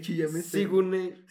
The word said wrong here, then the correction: Sigourney: ¿Cómo se dice Wanda? Sigourney Weaver. Eso.